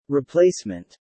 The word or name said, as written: replacement